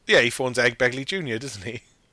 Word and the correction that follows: Ed Begley: can I say it right? I said Egg Begley. Ed Begley Jr. Yes.